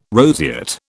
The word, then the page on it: roseate: British English